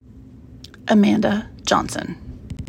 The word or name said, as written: Amanda Johnson